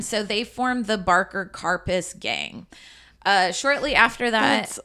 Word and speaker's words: Karpis: K-A-R-P-I-S. – Karpis. And I watched a couple videos because I was like, is it Karpi? Like French try pronunciation. Try to French it up a little bit. – Karpi. – Dierte. – It wasn't. It's Karpis.